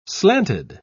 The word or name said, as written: slanted